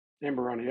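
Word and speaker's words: Amburana: And interesting enough, it's finished in Amberana barrels. Did I say that right? – Amberana, yep.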